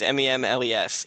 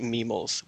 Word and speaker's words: Memles: It is a nickname from my childhood, and I have always pronounced it Memels, which makes no sense. – Okay. – Versus any. However, for the most part, I let people say Memles because it actually is how it looks.